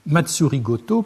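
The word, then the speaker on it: Matsuri Goto (まつりごと): Le sinogramme Sei était alors prononcé en japonais Matsuri Goto pour désigner les rites, et J exprime l'action de diriger.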